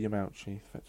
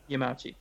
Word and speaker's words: Yamauchi: Mr. Yamauchi. – Yamauchi, thank you.